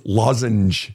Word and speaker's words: lozenge: And, but then she'll say, but so you don't pronounce the R at the end of the word? I said, Karen, there's no R at the end of lozenge. It's lozenge. It, it, just she doesn't make sense to her.